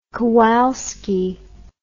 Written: kowalski

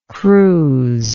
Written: cruse

